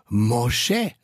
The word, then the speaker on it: Moshe: Moshe, so wird der Name auf Hebräisch ausgesprochen.